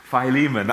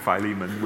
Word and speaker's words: Philemon: And this was the situation that Paul confronted Mandy's now changed my pronunciation of this. Philemon, I call him Philemon with in our epistle.